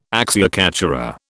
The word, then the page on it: acciaccatura: British English